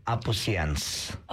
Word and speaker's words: Apusians: So, anyway, to correct it, the pronunciation of Apusians.